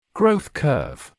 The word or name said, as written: growth curve